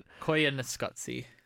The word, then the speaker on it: Koyaanisqatsi: Koyaanisqatsi. – Koyaanisqatsi.